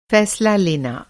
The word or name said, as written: फ़ैसला